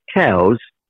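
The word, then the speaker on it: Coues: Yeah, the way I understand it, it's named after Elliott cows, pronounced like the other animal. However, anybody that is hunting them seriously calls them coos deer. – Okay. – More like COOS, basically.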